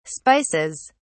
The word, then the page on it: spices: British English